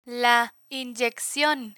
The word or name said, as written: la inyeccion